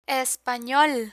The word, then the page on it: espanol: Spanish